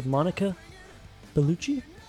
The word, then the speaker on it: Bellucci: Well, Stockland did with Monica Bellucci.